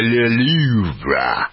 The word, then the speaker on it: Louvre: Yeah, en français, le Louvre. That's how they pronounce it over there.